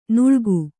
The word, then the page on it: ನಾಳಿಗೆ: nāḷige